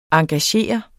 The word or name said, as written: engagere